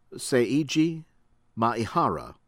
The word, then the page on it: Seiji Maehara: American English